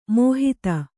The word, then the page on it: ಮಾಹತ್ತು: Kannada